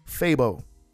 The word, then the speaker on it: Phabo: His name is spelled P-H-A-B-O. Fabo.